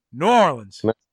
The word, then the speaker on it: New Orleans: It's like it's one word. New Orleans.